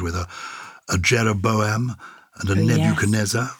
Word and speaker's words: Nebuchadnezzar: Nebuchadnezzar. – Oh, Nebuchadnezzar. You can pronounce it. – Okay. – Nebuchadnezzar. – Nebuchadnezzar. – Nebuchadnezzar. – You don't pronounce the D in it then, Nebuchadnezzar.